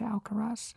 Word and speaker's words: Alcaraz: I can see Alcaraz. Is that how you pronounce it, Alcaraz? – Exactly.